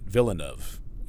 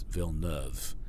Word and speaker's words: Villeneuve: So, for instance, um, you know the the Circuit Gilles Villeneuve, I was pronouncing it Villeneuve, and people said, "Well, it's it's Villeneuve," you know, or um, the the Maritime Province, uh, Newfoundland, it's not Newfoundland, it's Newfoundland.